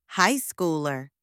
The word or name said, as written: high schooler